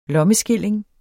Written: lommeskilling

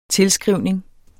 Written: tilskrivning

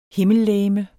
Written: HIMMELLEGEMETS

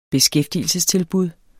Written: beskæftigelsestilbud

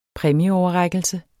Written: præmieoverrækkelse

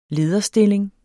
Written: lederstilling

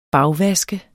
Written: bagvaske

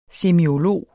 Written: semiolog

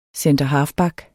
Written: centerhalfback

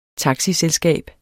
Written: taxiselskab

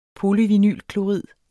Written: polyvinylklorid

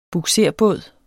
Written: bugserbåd

